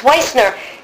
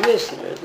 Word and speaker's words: Weissner: It's Weissner, as in Weiss. – Yeah, you see, the English pronunciation is Wissner. The German pronunciation is Weissner. – Lalette Jenkins Weissner. – Just use the word wise. – Yes. – Like the wise of Wow.